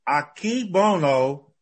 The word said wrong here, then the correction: Akebono: Aki Bo- yeah, Akebono and Musashimaru.